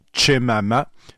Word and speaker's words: Chemama: C'est publié chez Dargaud par Alice Chemama. exactement comme ça se prononce, avec un CH au début, CHEMAMA.